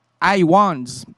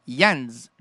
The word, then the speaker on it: Hyans: C'est tout a commencé par deux ou trois réunions chez Joe Iwans. Joe Iwans. Joe Iwans. Iwans, Iwans, Iwans, Iwans, Iwans, Iwans, écoutez, ça s'écrit H-Y-A-N-S. Yans. Joe Yans.